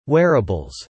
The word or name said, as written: wearables